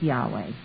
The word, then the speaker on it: Yahweh: they put in three syllables and made it Jehovah, and the two-syllable people were the Yahweh people. Well, since the Hebrew had no vowels, I'm going to stick with Yahweh. It's closest. It's only two syllables.